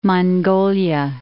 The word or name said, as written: Mongolia